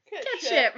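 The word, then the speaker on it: Cat Sìth: So in Scottish and Irish folklore, there's a big black cat with a white patch on his chest, and it's called the Ketshi, which is spelled Cat Sith. So thanks, Ireland, once again. – With the weird spelling. – Yeah, I Googled. I was like, how do I say this? I assumed it's cat something. No, it's Ketshi. Ketshi. Ketshi. Rather than Cat Sith, which sounds like an awesome thing in the next Star Wars movie.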